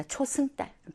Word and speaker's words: 초승달: 자, 그러면, 예, 초생달이 아니라 초승달. 발음을 잘못했어요? 초승달.